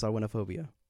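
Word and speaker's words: Samhainophobia: I'll tell you how it's spelled. It's spelled SAMHAINO phobia. – That doesn't make any sense. – That doesn't make sense, but that's how it's pronounced. Sawinophobia.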